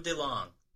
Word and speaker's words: DeLonge: Tom DeLong.